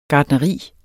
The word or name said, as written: gartneri